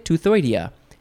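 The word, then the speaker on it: Teuthoidea: Toothoidea. T-E-U-T-H. It's not the tooth in your head. I don't know if that's related. This is spelled differently.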